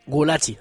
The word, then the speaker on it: Gulácsi: Bueno, habéis escuchado hoy al final cómo Negreoles corregía la pronunciación y es que se pronuncia Peter Gulácsi.